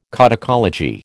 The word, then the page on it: codicology: American English